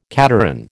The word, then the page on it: cateran: American English